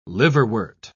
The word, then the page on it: liverwort: British English